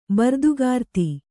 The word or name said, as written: ಬರ್ದುಗಾರ್ತಿ